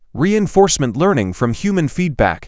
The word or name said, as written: Reinforcement learning from human feedback